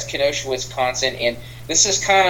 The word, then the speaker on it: Kenosha: Well, we are going into a serious conversation today because we are going to be talking about Kenosha. I believe I'm saying that correctly. – Yeah. Kenosha, Wisconsin. I'm not trying to be funny here,